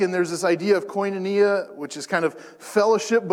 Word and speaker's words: koinonia: Last week we talked about this word koinonia, and that's koinonia is the correct pronunciation there.